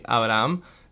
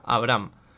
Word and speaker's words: Abraham: Si alguna vez eh, hasta ahora dije Abraham, yo pensé que se pronunciaba así, pero supuestamente era Abraham.